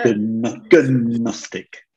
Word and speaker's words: Gnostic: Gnostic. I think with the G pronounce it's better. – Yeah. – Gnostic.